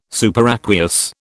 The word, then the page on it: superaqueous: British English